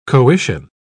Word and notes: coition: British English

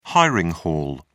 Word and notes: hiring hall: British English

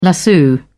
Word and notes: lasso: British English

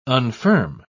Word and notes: unfirm: British English

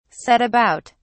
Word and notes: set about: British English

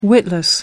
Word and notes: witless: American English

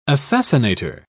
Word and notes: assassinator: British English

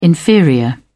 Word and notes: inferior: British English

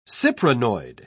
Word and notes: cyprinoid: English; British English

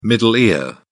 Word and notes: middle ear: British English

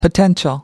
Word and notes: potential: American English

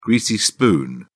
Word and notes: greasy spoon: British English

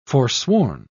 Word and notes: forsworn: British English

